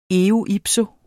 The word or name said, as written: eo ipso